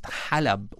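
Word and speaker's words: halab (حلب): And Aleppo in Arabic is pronounced halab, which means to milk.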